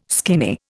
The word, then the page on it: skinny: Indian